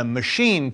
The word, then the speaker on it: machine: In the machine case, the whole setup leads you to expect a hard C in the next Mac word, not the soft sh of machine.